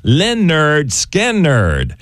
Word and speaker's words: Lynyrd Skynyrd: The album pronounced Lynyrd Skynyrd.